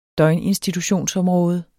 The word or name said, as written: døgninstitutionsområde